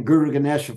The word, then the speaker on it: Guru Ganesha: He calls me G because my first name, Guru Ganesha, five syllables, a little much for most people.